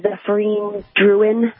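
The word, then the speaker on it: Zéphirine Drouhin: It's called a Zephyrine Druin.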